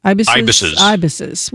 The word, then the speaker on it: ibises: What about the, is it ibises? Is that how you say it? Ibises? – Ibises.